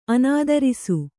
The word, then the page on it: ಅನಾದರ್ಶ: anadarśa